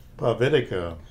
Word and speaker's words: Povitica: P-O-V-I-T-I-C-A, but depending on which country you're from, it's is how you pronounce it. There's it's pronounced Povitica, Pavitica, Povitica, but it kind of depends on which country you're from as to how you pronounce it,